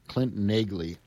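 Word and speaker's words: Neagley: And by the way, The Earl from 1941 also had solos from Clint Nagley, and that's correct pronunciation, Clint Nagley on the alto saxophone.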